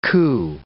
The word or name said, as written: coo